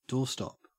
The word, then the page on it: doorstop: Australian English